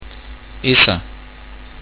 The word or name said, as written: issa